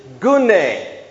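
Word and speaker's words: Gune (γυνή): In verse 3, the Greek word for woman is gune.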